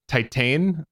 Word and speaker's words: Titane: So I walked to my local art house theater and I saw the Palme d'Or winning French movie Titane.